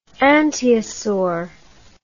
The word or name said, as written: anteosaur